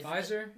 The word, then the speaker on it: Pfizer: Is Pfizer.